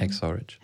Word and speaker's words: Hacksaw Ridge: Hacksaw Ridge.